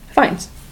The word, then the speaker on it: Fiennes: Fiennes.